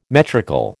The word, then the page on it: metrical: American English